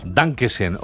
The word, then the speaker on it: Dankeschön: Dankeschön, o como se diga. ¿Lo he dicho bien? – Lo has dicho perfecto.